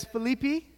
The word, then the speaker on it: Philippi: Now Philippi, it's, I was talking to my Greek friend last night, and I was corrected that it's Philippi, is how you say it the right way, and there's a lot of like, even me saying that right there, Philippi, is not necessarily correct.